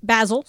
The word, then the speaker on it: Basel: So Art Basel has kicked Basil. – Off. Basel. Sorry, my bad.